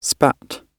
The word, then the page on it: spat: British English